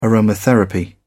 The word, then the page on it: aromatherapy: British English